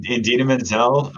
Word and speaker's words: Idina Menzel: Idina Menzel. – Yeah. Idina Menzel.